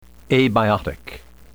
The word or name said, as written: abiotic